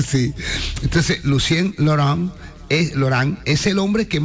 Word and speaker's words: Lucien Laurent: Lucien Laurent.